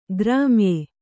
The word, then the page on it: ಗ್ರಾಮ್ಯೆ: grāmye